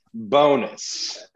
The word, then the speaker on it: Bowness: We're going to change up pronunciation. Bonus. I got the official scoop on that. – Are you sure? – Okay. – Bonus. – Yeah, Rick Bowness. It's not Bowness.